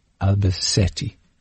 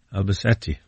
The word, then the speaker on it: Albacete: He reliably informs me that our emailer, Will, who lives in Helen in the town of, he says the correct pronunciation is Albacete. – Albacete, very good. – SETI, as in the search for extraterrestrial intelligence. Well, it's nothing to do with that, but that, but that's how you pronounce it.